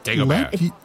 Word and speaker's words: Dagobert: So, Dagobah. What Dagobert. – Dagobert. – Oh, sorry, Dagobert. – The T is silent.